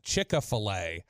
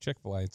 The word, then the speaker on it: Chick-fil-A: 469, Chick-fil-A, correct, was Chick-a-fil-A, how my southern butt says it. Chick-a-fil-A instead of Chick-fil-A.